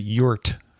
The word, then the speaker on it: EURT: I do want to pronounce it yurt, the E-U-R-T. – Yurt. – Yurt.